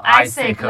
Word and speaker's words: AIESEC: Hello，大家欢迎来到泡泡星球之 A I E S E C，其实念作 Isaac 啦。